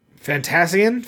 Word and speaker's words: Fantasian: How do you say that? Fantasane? – Fantasian. – Fantasian. I hate the English language.